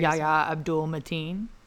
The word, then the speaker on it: Yahya Abdul-Mateen: Yahya Abdul-Mateen.